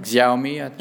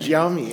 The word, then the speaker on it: Xiaomi: Xiaomi. – Xiaomi, yeah.